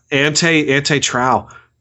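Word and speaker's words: Antje Traue: It's like A-N-T-J-E. – I see it, yeah. – T-R-A-U-E. Ante Trow.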